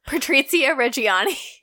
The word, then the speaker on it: Patrizia Reggiani: I'm so American. I'm like, Patricia Reggiani. Patrizia Reggiani.